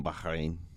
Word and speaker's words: Bahrain: Today's was from Bahrain, as a coworker explained to me. That's how you pronounce it.